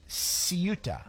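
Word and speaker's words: Ceuta: Ciuta is how it's pronounced.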